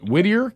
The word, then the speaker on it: Whittier: Is it Whittier, California? Is that how you say it? – Yes, sir.